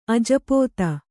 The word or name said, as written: ಅಜಪೋತ